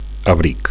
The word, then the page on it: abric: Catalan